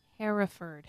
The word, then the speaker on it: Hereford: Because I say it wrong, and it looks like Hereford, but it's... – Hereford. – Hereford. Hereford, England.